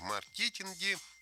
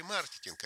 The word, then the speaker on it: маркетинг: В маркетинге… Вот раньше говорили маркетинг, а сейчас я посмотрел, правильно говорить маркетинг.